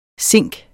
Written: zink

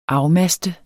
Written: afmaste

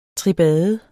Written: tribade

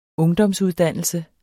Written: ungdomsuddannelse

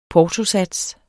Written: portosats

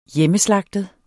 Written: hjemmeslagtet

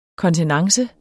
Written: kontenance

